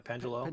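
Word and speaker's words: Péndulo: And I've mentioned this several different times that I think Pendulo Studios, is that how you say it? – Pendulo.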